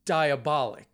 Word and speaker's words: Diabolik: Yeah, because I always go, for some reason, I always want to pronounce it Diabolik double feature, but it's Diabolik, right? – That's how we pronounce that spelling, yes.